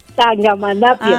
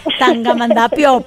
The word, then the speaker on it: Tangamandapio: Tangamandapio. – Ah, mira, este es un trabalenguas, Tangamandapio, por eso no lo dije bien.